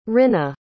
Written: rinnah

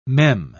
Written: mem

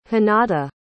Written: hinata

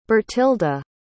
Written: Bertilda

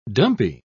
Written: dumpy

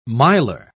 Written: miler

